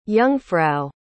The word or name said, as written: jungfrau